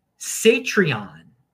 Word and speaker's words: Satreon: Satreon which rhymes with Patreon, is a non custodial Lightning version of Patreon and similar to Y'alls and other paywalls.